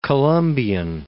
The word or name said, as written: British Columbian